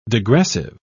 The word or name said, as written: degressive